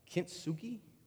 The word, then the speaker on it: kintsugi: It's kintsugi.